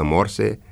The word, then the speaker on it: Morse: Or, susține Graur, ar trebui să pronunțăm exact așa cum se pronunță numele inventatorului și pictorului, adică, așa cum ați auzit, Morse. Sincer să fiu, eu mereu am pronunțat cum se scrie, adică morse, și nici nu știu cum să vă sfătuiesc, asta fiindcă nici Dexul, nici Domul nu ne spune cum să pronunțăm.